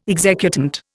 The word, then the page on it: executant: Indian